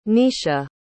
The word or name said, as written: nicia